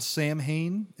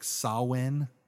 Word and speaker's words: Samhain: so halloween was originally derived from the celtic holiday well or the celtic time uh, uh known as it's spelled Samhain, and a lot of people pronounce it that way. I know that a lot of people would say that the Gaelic pronunciation is like Samhain, like kind of like mm-hmm.